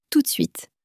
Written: tout de suite

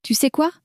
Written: tu sais quoi

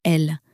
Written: elle

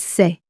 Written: c'est

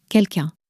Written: quelqu un

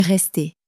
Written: rester

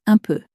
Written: un peu

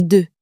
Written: deux

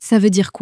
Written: ca veut dire quoi

